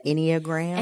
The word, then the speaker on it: Enneagram: Enneagram.